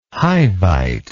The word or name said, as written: hivite